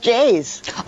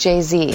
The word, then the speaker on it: Jay-Z: Hi, I'm Betty White, and I'm hosting Saturday Night Live this week with musical guest Jay's. – Oh, actually, Betty, I'm sorry. I think it's pronounced Jay-Z.